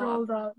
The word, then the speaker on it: Roald Dahl: Do you think, okay, it might be a bit unrelated, but do you think all these amazing writers, like Roald Dahl, is that how you pronounce it? Roald Dahl? – Roald Dahl, yeah.